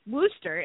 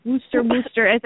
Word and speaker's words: Worcester: Worcester is a city in Massachusetts and Worcester. – Okay. Worcester.